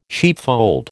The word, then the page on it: sheepfold: American English